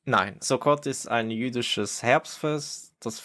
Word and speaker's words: Sukkot: Genau, also Sukkot ist richtig ausgesprochen.